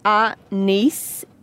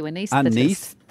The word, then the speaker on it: anaesthetist: Guys, I know how you say it. – Yeah. – It's anaesthetist. – Thank you, anaesthetist.